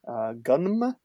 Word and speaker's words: Gunnm: Gunm. I don't know if I'm supposed to pronounce the M there. G-U-N-N-M.